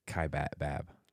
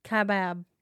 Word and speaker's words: Kaibab: One checker said that we mispronounced Kaibab. It's Kaibab. – Kaibab. – Kaibab, yeah.